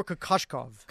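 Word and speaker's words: Kokoškov: Igor Kokoschkov. – So like K-U, phonetically, K-U-A-T-I-P. – Yeah, so it's K-O-K-O-S-K-O-V, Kokoschkov. So I guess the S is more of a Kokoschkov.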